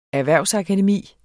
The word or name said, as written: erhvervsakademi